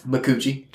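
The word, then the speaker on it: Micucci: What, how do you pronounce her last name? – McCoochie. – Just checking.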